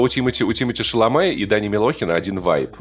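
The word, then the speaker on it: Chalamet: А почему не знает, как правильно произносить фамилию Тимати Шаломе? Почему? – Потому что правильная фамилия Тимати Шаламе, естественно, Тимати Шардане, потому что ну, он создан для Вообще...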